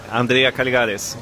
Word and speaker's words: Andrea Caligaris: Jag glömde hur man uttalar ditt namn, kan du säga det igen? – Andrea Caligaris. – Ja, just det, så var det.